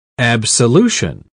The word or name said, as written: Absolution